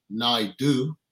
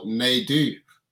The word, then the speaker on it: Naidoo: It's French Creole. So you could say Naidoo or Naidoo. Depends where you come from and how you want to say it.